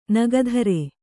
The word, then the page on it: ನಗಧರ: nagadhara; Kannada